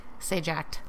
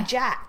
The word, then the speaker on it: Sajak: Sajacked. I don't know why. – Sajak. – I know what it is. Oh, okay. I can't make my mouth say it. Pat Sajak.